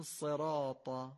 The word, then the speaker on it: صراط: باید خیلی معمولی، به جای اینکه بگیم صراط، بگیم صراط، صراط.